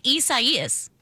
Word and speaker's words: Isaias: It looks like Isaiah, but without the H. – Spell it. Can you spell it for me? – I-S-A-I-A-S. – And it's four syllables. I do know that. I-S-A-I-A-S. And it's like I-S-A-I-A-S.